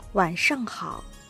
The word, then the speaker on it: 晚上好: 晚上好。